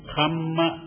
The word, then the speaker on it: kamma: And this word karma is Sanskrit form is karma, K-A-R-M-A. The Pali form is kamma, K-A-M-M-A. Kamma. But some people spell it wrong as K-A-M-A. So if you say K-M-A, it is not kamma, it is kama. It has a different meaning.